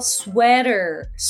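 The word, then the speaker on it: sweater: とセーターは、発音はスウェーター、スウェーターです。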